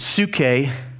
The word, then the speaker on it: psyche: But the word soul is actually the word, they pronounced it psyche, P-S-Y-C-H-E, we would read it as psyche.